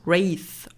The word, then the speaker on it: Wraith: Äh, ich würde sagen, das spricht man Wraith aus. Wraith, okay, ja das Wort schauen wir uns nämlich an.